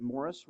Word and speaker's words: Maurice: What this is a collection of essays that Morris Roberts, I know it looks like Maurice, but he pronounces it Morris, Morris Roberts, wrote for the Banner of Truth magazine.